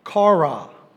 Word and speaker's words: chara: Uh, the Greek word for joy is actually C H A R A, and it's pronounced kara.